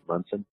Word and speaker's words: Monson: So if you just put in Kim, it looks like Monson, but she pronounces it Munson.